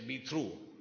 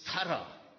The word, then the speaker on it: thorough: And of course, some of us who didn't know much English, we'd say, be through. Yeah. It's thorough. Two syllables.